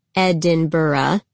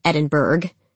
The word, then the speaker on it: Edinburgh: The city, which it turns out is pronounced Edinburgh and not Edinburgh, is more than 900 years old.